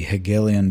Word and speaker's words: Hegelian: Yeah, the term is the Hegelian dialectic, if I'm pronouncing it properly.